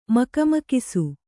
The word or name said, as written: ಮಕಮಕಿಸು